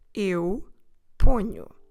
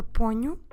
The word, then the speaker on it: ponho: So we have to do a ny, ny, ponho. Make sure you stress the first syllable, pon. That O gets a bit nasal because of the proximity with the nasal consonant sound we have afterwards. So we start with a pon, ponho.